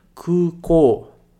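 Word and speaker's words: kukou (空港): E a gente fala kukou. Kukou. Lembra sempre de prolongar essas duas sílabas, beleza?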